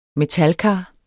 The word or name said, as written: metalkar